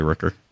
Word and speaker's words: Rooker: Is that how you pronounce his name? Rooker. – I would say Rooker.